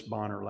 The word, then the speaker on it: Bonar: Horatius Bonner, last name is spelled B-O-N-A-R. It's the Scottish pronunciation.